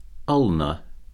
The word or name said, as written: ulna